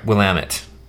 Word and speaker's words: Willamette: Willamette. That's the right way to pronounce that.